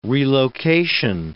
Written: relocation

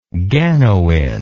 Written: ganoin

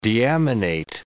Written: deaminate

